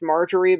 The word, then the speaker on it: Margerry: I think it's also supposed to be pronounced Marjorie, but it's spelled differently. M-A-R-G-E-R-R-Y. – Yeah, that's Marjorie. – Yeah. That's her mom's name is Marjorie, but spelled M-A-R-J-O-R-I-E.